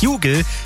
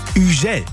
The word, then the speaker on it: Hugel: Er heißt nicht Hugel, er heißt auch nicht Hugel, sondern er heißt Hugel. Kommt nämlich aus Frankreich und ist nicht nur da, sondern mittlerweile weltweit ein ziemlich bekannter DJ und Produzent.